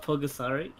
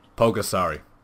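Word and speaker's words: Pulgasari: How do I spell it? Pogasari? – Pogasari. P-U-L... S-A-P-U-L. Pogasari. Yes, P-U-L-G-A. Yeah.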